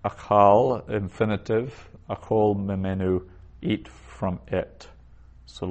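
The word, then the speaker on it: achol (אֲכֹל): Notice I said achol, the vowel there for achol, that second vowel is a short O instead of a long A. It's a closed, unaccented syllable.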